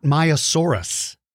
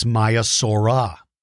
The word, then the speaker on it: Maiasaura: It's not Maiasaurus, it's Maiasaura. And that one is mispronounced oftentimes. Like, for instance, you know, Stegosaurus, Tyrannosaurus, um, uh, Allosaurus. They always end in R-U-S. But this one ends in the letters R-A, Maiasaura.